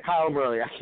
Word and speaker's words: Kyler Murray: You're Kyler Murray? – Yeah, Kyler Murray. Kyler Murray. I keep on saying his name wrong.